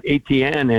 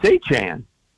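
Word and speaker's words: Etienne: And in his first press conference, you know, he says it's not ATN anymore. And I live not far away from a family of ATNs. They spell it the exact same way, said it that way. And he said, it's A-chan.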